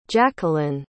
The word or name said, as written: jaqueline